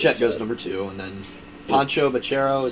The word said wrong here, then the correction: Paolo Banchero: is that how you say his name? – Pancho. – How do you say his name? I have no idea. – Paolo Banchero.